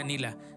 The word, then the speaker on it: Neela: Si la buscan, Nila se escribe N-E-E-L-A, Nela, pero se pronuncia Nila.